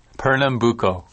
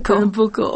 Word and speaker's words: Pernambuco: How do you say it? – Pernambuco. – Oh, see, you got it right. Pernambuco, okay.